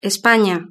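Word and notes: espana: Spanish (Spain)

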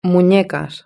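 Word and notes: munecas: Spanish (Spain)

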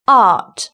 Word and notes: art: British English

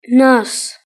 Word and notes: nurse: British English

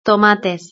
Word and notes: tomates: Spain Spanish

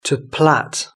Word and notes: to plait: British English

